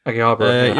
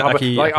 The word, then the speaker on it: Akihabara: Is it like, is it Akihabara? Is that how you pronounce it? – Akihabara. – Yeah, Akihabara.